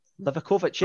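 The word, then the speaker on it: Livaković: Livakovic, yeah.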